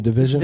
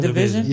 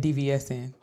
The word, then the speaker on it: DVSN: So that's how you pronounce her name? – Ooh. Yeah, Division. – Division. – Yeah, absolutely. – How did you think it was pronounced? – I've been saying DVSN.